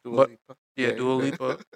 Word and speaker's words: Dua Lipa: Yeah, so Dua Lipa, I hope I'm saying her name right. – Dua Lipa.